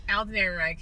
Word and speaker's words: Alden Ehrenreich: Alden Ehrenreich, he is a gem.